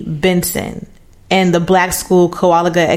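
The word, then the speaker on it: Kowaliga: Kowalgi. – Mm-hmm. – Am I saying it right, guys? – Yeah. – Kowalgi.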